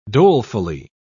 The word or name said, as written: dolefully